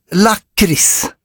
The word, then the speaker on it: lakrits: Jag noterar att allt fler yngre stockholmare använder det korrekta lakritsuttalet lakrits.